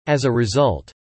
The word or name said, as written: as a result